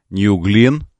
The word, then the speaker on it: New Glenn: Согласно трансляциям сайтов и Space, мегаракета New Glenn, правильно говорить, ну, в честь астронавта, компания Blue Origin взорвалась во время испытаний на стартовой площадке на мысе Канаверал.